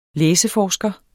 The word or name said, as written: læseforsker